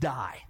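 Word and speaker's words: die (δεῖ): And I want to take a deeper look at that phrase there, ought ye, because in the Greek, it is D-E-I, and it's pronounced die.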